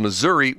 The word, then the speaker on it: Missouri: If you pronounce Missouri with an "ah" at the end, you're probably from Missouri.